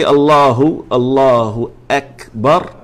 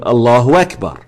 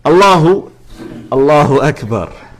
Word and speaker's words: Allahu Akbar: If you don't say Allahu Akbar, then you're saying Allahu Akbar, which is different. All right. Allahu Akbar.